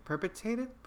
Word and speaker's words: perpetrated: Um, I, I for a second, for a quick second, I was just like, oh wow, is this another murder that's perpetua- perpetuated, perpetrated? – How do you say that? Perpetrated. You're just not hitting the R.